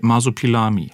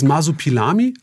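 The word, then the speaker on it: Marsupilami: Wolfgang Kotzian aus Blaustein möchte wissen, warum es eigentlich zu der Umbenennung kam von Kokomikos in Masopilami. Und ich habe es wahrscheinlich schon falsch ausgesprochen. – Nee, es ist im Großen und Ganzen richtig. Also das Masopilami, also Masopilami ist auch der französische Name.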